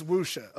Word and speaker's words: Wuxia: So it's Wuxia. Officially, it is Wuxia.